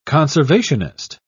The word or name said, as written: conservationist